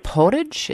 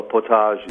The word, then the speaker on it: potage: The soup that we were going to offer is the roasted squash potage. Is that how you pronounce that? – Well, potage. – Potage.